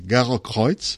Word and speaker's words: Gerokreuz: en alemany s'anomena Gerokreuz, que ja fa temps que no vaig en Alemanya, però es pronuncia així, Gerokreuz,